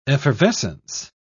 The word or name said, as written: effervescence